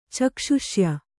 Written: ಚಕ್ಷುಷ್ಯ

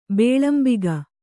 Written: ಭಾಲಾಂಬಕ

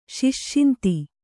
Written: ಶಿಷ್ಷಿಂತಿ

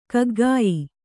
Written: ಕಗ್ಗಾಯಿ

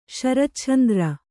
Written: ಶರಚ್ಛಂದ್ರ

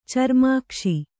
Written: ಚರ್ಮಾಕ್ಷಿ

